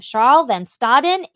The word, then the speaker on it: Scharl van Staden: So we are going to welcome Scharl van Staden into the studio.